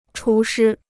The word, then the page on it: 厨师: chú shī